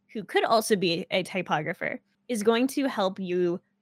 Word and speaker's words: typographer: Your typographer is the person. Typographer. – That's how you pronounce it.